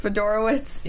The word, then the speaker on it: Fedorowicz: I think once they started getting DeAndre Hopkins into the game, what's his Fedorovich? Is that how you say it? – Fedorowicz. Yeah.